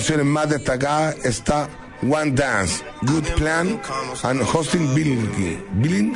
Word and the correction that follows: Hotline Bling: ¿Cómo se pronuncia eso? Hotline Bling.